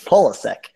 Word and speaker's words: Pulisic: Did I say that correctly? There we go. – Yeah, second close enough. – You got it. – Pulisic.